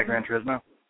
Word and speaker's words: Gran Turismo: the Gran Turismo, is that how you say it? – Yeah, Gran Turismo.